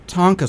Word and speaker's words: Tonka: And you're president of the Tonka Society of America. Am I pronouncing that correctly? – You are.